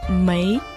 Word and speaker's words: 梅: Слово мэй произносится вторым тоном, имеется в виду дикая слива.